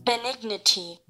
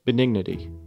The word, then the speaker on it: benignity: How do you say it? Benignity. Benignity. Benignity. Okay. Yeah, so it sounds like dignity, but it's benignity.